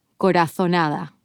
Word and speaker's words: corazonada: You'll hear that the Z is pronounced like TH in the English word think, instead of S, like in Latin America. So corazonada becomes corazonada.